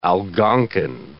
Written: Algonkin

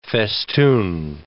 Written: festoon

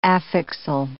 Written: affixal

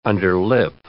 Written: underlip

